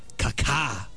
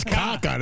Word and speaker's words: Kaká: K-A-K-A. – That's Kaká. That's not Kaká. That's Kaká. – He's got the little accent on the second syllable.